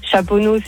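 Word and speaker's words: Chaponnay: T'as Saint-Priest, c'est EST, tu le prononces. Chaponneau, c'est ST, tu le prononces pas.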